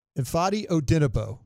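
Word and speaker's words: Ifeadi Odenigbo: Ifadi Odenebo.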